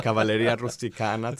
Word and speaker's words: Cavalleria Rusticana: Yeah, Cavalleria Rusticana to start with.